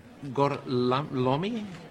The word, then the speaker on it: Gorlami: Corlomi? Lo pronuncio correttamente? – Corretto. – Gorlami?